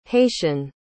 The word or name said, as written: haitian